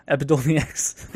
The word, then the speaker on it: Epidiolex: Nope, yeah, Epidiolex. I said it right the first time.